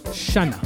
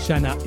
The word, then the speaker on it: Szajna: and then Shanna, it's pronounced, I think. spelled S Z A J N A. Brilliant broken beat producer out of the UK. Shanna is Sweeter is the name of that.